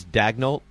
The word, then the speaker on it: Daigneault: Is that the way? I'm not being a jerk now. – Yes, that's how it's pronounced. – It's Daigneault? – Yes. – Okay. – It looks like it could be like Deneau.